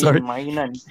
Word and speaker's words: Maignan: Yeah, Maignan. – He has 21 clean sheets. – Don't make his name into an Indian vulgarity, bro. – Yeah, Maignan.